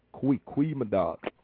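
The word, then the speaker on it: Goeie middag: So your G is looks like a G, but it's actually pronounced in the Q. Kwe mid dog.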